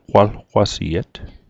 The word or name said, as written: qwalqwasiet